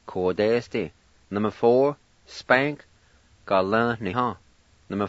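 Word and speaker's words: galivniha: And before I pronounce this word, I want to mention that I with the L most time is silent. And we pronounce it like this. Ga-la-ni-ha.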